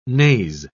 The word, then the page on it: naze: British English